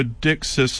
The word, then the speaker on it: dickcissel: And tonight we're going to hear about a small songbird known as the dickcissel. I'm going to spell that D-I-C-K-C-I-S-S-E-L. It's a one word name like mallard, share, sting, prince, redhead, the duck. So dickcissel. And the name is an example of onomatopoeia, suggesting the bird is saying its name.